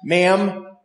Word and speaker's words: Ma'am: For example, you should address her as Your Majesty, and then subsequently as Ma'am with a short A, like it rhymes with jam.